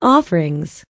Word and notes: offerings: American English

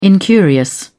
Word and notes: incurious: British English

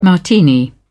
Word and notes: martini: British English

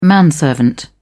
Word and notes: manservant: British English

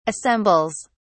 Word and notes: assembles: British English

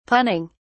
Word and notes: punning: British English

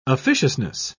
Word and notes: officiousness: British English

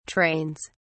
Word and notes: trains: British English

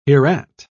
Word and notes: hereat: British English

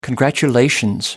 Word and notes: congratulation: American English

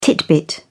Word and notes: titbit: British English

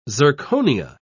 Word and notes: zirconia: British English

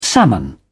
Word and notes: salmon: British English

